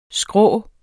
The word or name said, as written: skrå